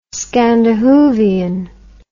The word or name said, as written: scandahoovian